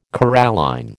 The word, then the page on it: coralline: American English